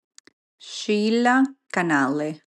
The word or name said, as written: Sheila Canalle